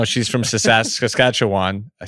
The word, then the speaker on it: Saskatchewan: How do you say that? – Saskatchewan. – Saskatchewan. I never know how to say that.